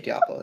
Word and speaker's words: Diablo: Sorry, is it Diablo? No, you should say Diablo. That's how it's pronounced in Spanish.